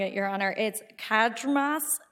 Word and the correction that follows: Cadrmas: v. Dickinson Public Schools. It's cited on page 16 of the reply brief, and the first word is Cadrmas.